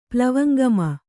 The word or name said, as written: ಪ್ಲವಂಗಮ